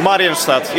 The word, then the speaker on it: Maryensztadt: How do I pronounce the name? Browar Maryensztadt. – Maryensztadt, yeah. It's the right pronunciation. Actually, the name uh, derives from a German language, not from the Polish language, so Marienstadt.